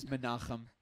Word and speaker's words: Menachem: My name's Menachem. That's how you say it cool, by the way.